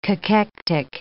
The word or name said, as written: cachectic